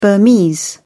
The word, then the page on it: burmese: British English